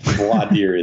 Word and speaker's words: voir dire: It is very often voir dire here, but voir dire is correct.